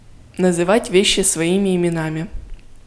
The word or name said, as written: называть вещи своими именами